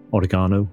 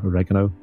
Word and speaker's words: oregano: Uh, and maybe sort of local herbs of the area, oregano or oregano, depending on how you pronounce it, that kind of thing.